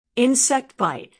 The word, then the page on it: insect bite: American English